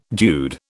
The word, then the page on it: dude: British English